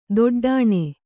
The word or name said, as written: ದೊಡ್ಡಣ